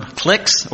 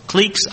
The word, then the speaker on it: cliques: That's why we use the term cliques or cliques. I looked up how to pronounce that. Either one's acceptable.